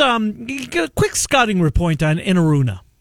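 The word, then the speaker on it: Enaruna: How do you say his name? Inaruna? Is Tristan Inaruna? – Yep, you nailed it right there.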